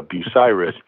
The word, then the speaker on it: Bucyrus: So I learned something that Place in between, yeah, it's spelled Bucyrus, but uh, Bucyrus, um, it's how it's pronounced.